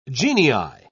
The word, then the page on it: genii: British English